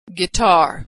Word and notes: guitar: g

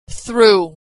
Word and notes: through: American English

